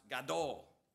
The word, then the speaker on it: gadol: That word in Hebrew, great, is pronounced gadol.